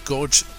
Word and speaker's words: Gorge: Am I pronouncing this well, Gorgie? – Beautiful. Oh, wait, no, you're talking about Georgia? – Is it Gorge, G-O-R-G-E? – G-O-R-G-E. – Anyway. – Gorge, yeah, you're right.